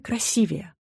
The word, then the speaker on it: красивее: Однажды уважаемый телеведущий, можно сказать, мэтр, сделал неправильное ударение в слове «красивее». Моя грамотная подруга засомневалась, неужели он прав? Может, норма изменилась? Но нет. Ударение в сравнительной степени «красивее» падает только на второй слог, и никак иначе.